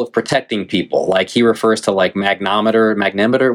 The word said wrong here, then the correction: magnetometer: whatever you call it. Like, magnetometer? – Yeah, magnetometer, yeah, I missed a syllable in there, but you know, like metal detectors, right?